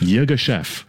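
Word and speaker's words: Yirgacheffe: But is that how you could pronounce it? Say that again. – Ethiopia Yogeshef. – Yogeshef. – That's right.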